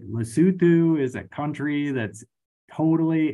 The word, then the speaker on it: Lesotho: But also another one that stood out was the Lesotho, which I was always calling it Lesotho because that's how it's spelled, but Lesotho, which it's kind of weird,